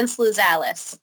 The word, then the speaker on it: Sluzalis: And Shailen, you can correct what I just can you say your last name for us? – Yes, no problem, David. It's Shailen Sluzalis.